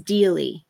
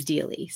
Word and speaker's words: Deally: It is Dealey.